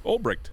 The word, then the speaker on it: Ulbricht: Ulbricht. Okay. You're right. Yeah. – All right. – Actually, Ulbricht.